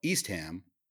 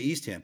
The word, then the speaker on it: Eastham: And there's another town on the Cape called East Ham. And I'm like, how do you say East Ham? Because obviously East Ham is East Ham. And he's like, it's Eastham. It is not Eastham.